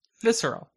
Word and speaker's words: visceral: Hold on. Viscral. I said it correctly. Wait, that's not volume. Visceral. Visceral. Visceral.